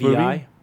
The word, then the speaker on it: MoVI: It's like, spelled weird, but M-O-V-I. Pronounced movie.